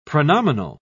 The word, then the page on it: pronominal: British English